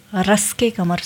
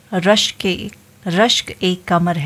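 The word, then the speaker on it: रश्क-ए-क़मर: बहुत से लोग इस गाने को रस के कमर समझ लेते हैं लेकिन इसका जो सही उच्चारण है वो रश्क ए कमर है।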